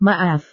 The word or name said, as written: maaf